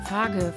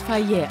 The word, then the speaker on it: Fage: Faye wird so oft verschieden ausgesprochen. Fage, Feige, Feitsch. Aber man spricht es Faye aus.